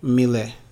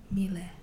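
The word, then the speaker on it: Miele: Mele. The E is silent. – Mele. Okay. – It's M-I-E-L-E, so the first E is silent. Mele.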